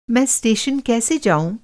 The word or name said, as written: कैसे